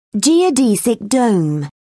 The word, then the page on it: geodesic dome: British English